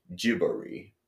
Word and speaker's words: Ghibli: and it's like in English it is Ghibli, in Japanese it is pronounced Jibori.